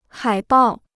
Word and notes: 海报: hǎi bào

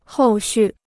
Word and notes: 后续: hòu xù

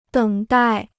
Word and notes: 等待: děng dài

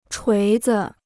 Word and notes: 锤子: chuí zi

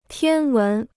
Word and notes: 天文: tiān wén

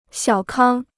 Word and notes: 小康: xiǎo kāng

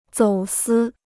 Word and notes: 走私: zǒu sī

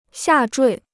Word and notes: 下坠: xià zhuì

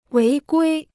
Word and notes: 违规: wéi guī

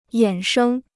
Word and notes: 衍生: yǎn shēng

